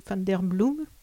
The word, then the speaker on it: van der Bloem: Et par ce petit décalage qui revient ici et là, tout au long du roman, je pense aussi à un paragraphe sur Pauline van der Bloem, on prononce comme ça. – Oui, c'est bien.